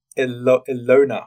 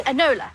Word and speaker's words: Enola: We keep accidentally pronouncing it Ilona Holmes, but we're determined not to do that tonight. Enola. Tamsin? – Enola.